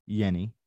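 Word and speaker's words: Jenny: It is pronounced Yenny. – Okay. – So this is no J, but Yenny.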